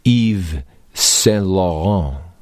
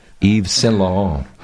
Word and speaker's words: Yves Saint Laurent: Now, of course, it's not so unusual because we've all heard of the designer Yves Saint Laurent. Did I say his name right? – Oh, you said it beautifully. – Not bad, huh? Yves Saint Laurent.